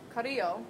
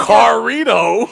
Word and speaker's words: Carrillo: L-I-N-C-E. Lince. – Humberto Carrillo. – That's what I said. – No, you said Humberto. – I said Humberto. – Carrito.